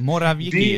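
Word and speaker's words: Morawiecki: Want eh, Arban en ook eh, de Poolse premier uh, Ma Ma zeg ik het goed? – Morawiki is het toch? Nou ja, Morawiki, sorry, ik zeg het ook even verkeerd.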